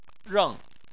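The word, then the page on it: reng: tone 4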